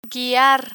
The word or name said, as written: guiar